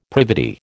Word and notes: privity: American English

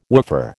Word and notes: woofer: American English

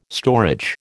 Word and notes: storage: American English

